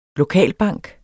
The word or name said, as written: lokalbank